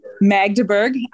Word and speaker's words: Magdeburg: And so in one example, this place in Germany called Magdeburg, maybe, I don't know if I pronounced that correctly. Huh. Excuse me, what? – Magdeburg. – I've been corrected.